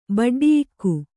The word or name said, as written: ಬಡ್ಡಿಯಿಕ್ಕು